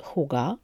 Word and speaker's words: hygge: Jest y, nią oczywiście takie e, znane zjawisko, postawa i styl życia, które wymawia się na bardzo różne sposoby, ale znajdę coś po środku, czyli huga, pisze się hygge,